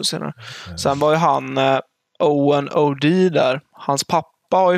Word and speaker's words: Eoghan: Han stavar alltså E-O-G-H-A-N. Eller Owen.